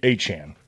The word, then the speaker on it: Etienne: Achan, apparently, is how he wants to be pronounced now.